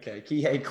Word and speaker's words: Kihei: Armand Franklin from Indiana, and they have that senior point guard, how do you say his name, Kahi Clark? Kihei Clark, who distributes the ball well.